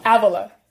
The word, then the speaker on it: Avila: Avila. You got it close. You're better than most people say it.